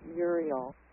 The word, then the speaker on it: Uriel: I pronounce it Uriel.